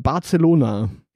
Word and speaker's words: Barcelona: Die Sagrada Familia in Barcelona. – Ja. – Übrigens, genau, das spricht man auch genau so. Barcelona, nicht irgendwie anders.